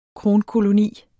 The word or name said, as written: kronkoloni